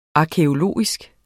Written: arkæologisk

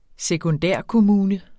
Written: sekundærkommune

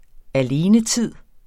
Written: alenetid

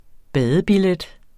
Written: badebillet

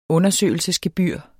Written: undersøgelsesgebyr

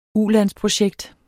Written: ulandsprojekt

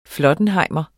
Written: flottenhejmer

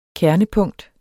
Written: kernepunkt

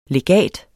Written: legat

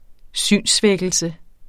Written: synssvækkelse

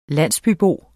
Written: landsbybo

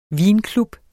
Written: vinklub

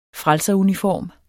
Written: frelseruniform

